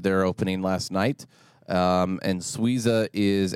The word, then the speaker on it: Sweeza: It's a little bit easier for us to pronounce here with this new spelling of Suiza, S-W-E-E-Z-A.